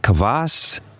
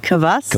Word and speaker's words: kvass: I see kombucha, and I see, is it pronounced kvass? – Beet kvass.